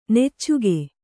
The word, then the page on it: ನೆಚ್ಚುಗೆ: neccuge